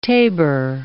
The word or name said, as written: tabour